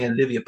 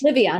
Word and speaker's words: Livia: You say it, because I think I'm saying Olivia, but it's Livia Wellness. – Livia. Yeah, it's like Olivia, but Livia.